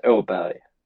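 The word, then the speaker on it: Åberg: But, you know, whenever I go back to Sweden, there's a little bit of a circle above my A, which makes it pronounced Aberg.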